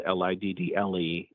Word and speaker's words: Liddle: And of course, just anywhere you get your podcast, just search Where You're Going or search my name, John Liddle. Last name is spelled Liddle, and you'll find it pretty easily.